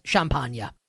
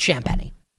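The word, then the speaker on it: Champagnie: Champagne. That should be how you pronounce his name, but it's Champagne.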